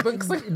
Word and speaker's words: BNXN: My friend pronounces it as Benson.